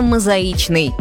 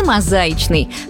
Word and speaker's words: мозаичный: Есть слова, которые часто произносят с ошибками. Мозаичный – одно из них. Да-да, именно мозаичный, не мозаичный.